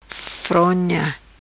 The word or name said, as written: Fronha